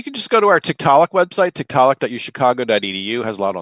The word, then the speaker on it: Tiktaalik: And Tiktaalik is spelled T-I-K-T-A-A-L-I-K, Tiktaalik Rosea.